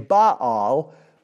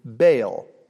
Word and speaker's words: Baal: Some languages would pronounce the name Baal, but I'm choosing to use the anglicized pronunciation Baal.